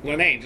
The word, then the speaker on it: Lenane: Lenane, just like it looks.